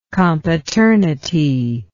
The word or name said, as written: compaternity